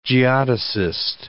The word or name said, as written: geodesist